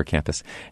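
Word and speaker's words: Campws: Even though it's C-A-M-P-W-S, that's the Welsh spelling for campus. So it's pronounced just like we would think, the Water Campus.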